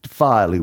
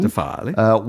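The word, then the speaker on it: Defialy: I'll spell it because I probably haven't pronounced it correctly. – Sounds like the wine that Robert Johnson drank. – I don't know. It's D-E-F-I-A-L-Y. Defily Wine. – Defily.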